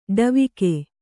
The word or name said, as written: ಡವಿಗೆ